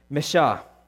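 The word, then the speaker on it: mashach: In fact, the word anointed used in 1 Samuel in the Hebrew is pronounced this way, Meshah. And out of that word comes Meshah or Messiah, which means anointed one.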